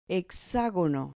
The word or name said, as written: hexagono